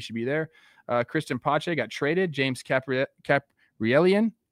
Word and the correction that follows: Caprielian: got released. – Caprielian. – I know that guy. He's like my buddy. I don't know why I didn't say that right.